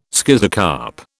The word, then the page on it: schizocarp: British English